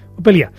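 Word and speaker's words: Up Helly Aa: Bueno, la idea era, des de l'altra vegada de l'estiu, que ens van parlar, que l'hivern feien una festa, nosaltres en diríem Up, Heli, Ha. – Però ells... – Ells diuen, Upelia. I quan parlaves, deies, ho pronunciaven superràpid i així.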